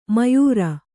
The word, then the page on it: ಮಯೂರ: mayūra